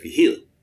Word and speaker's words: Vigil: And like, we're always Vigil back home. And so when I turned 18 and joined the Army, I just made it a point to say, I'm Eric Vigil. The proper pronunciation. – Did you get like the side eye, like, okay, Mr. Vigil. – As long as people won't put an R in it or Virgil, I'm good.